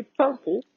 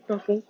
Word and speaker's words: Paimpont: Donc je me souviens que du nom de celle où on a été nous qui est Pimpon. Oui, Pimpon, comme les pompiers. Mais ça s'écrit P-A-I-M-P-O-N-T.